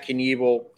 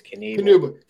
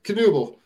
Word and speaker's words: Knuble: you have Knuble. I'm saying it wrong. I think it's Knuble. – Knuble. Think Flyers. Mike Knuble.